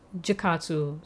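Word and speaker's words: Jakotsu: Kokatsu, see, the thing is, it's spelled J-A, but in the English dub version, they pronounce it as Kokatsu, but it's Jakatsu.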